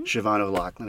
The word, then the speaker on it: Siobhan O'Loughlin: Siobhan O'Loughlin.